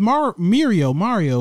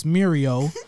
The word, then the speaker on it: Mirio: It's just the A is an I, so it's Mirio.